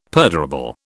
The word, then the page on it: perdurable: British English